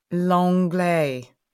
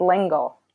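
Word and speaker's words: L'Engle: And your second bookshelfy choice is, I'm going to say her surname wrong, Madeleine Langlais. – No, you're real fancy. It's Lengel. I think it's Lengel. But yes, I'm sure originally it was closer to what you said.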